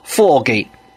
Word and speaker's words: 科技: 科技这个词儿，粤语也广东话读作 foggy，英语中雾蒙蒙这个词儿读作 foggy，两个发音几乎没有区别。